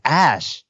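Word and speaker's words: Æ: Isn't the AE supposed to make like a weird, like, what was it? Elon's daughter's name has like a AE and it's supposed to be like, oh, it's Ash. It's pronounced as Ash.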